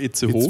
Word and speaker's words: Itzehoe: Spricht man das nicht eigentlich nur Itzehoe? Itz vielleicht, Oder ja genau, Itzehoe.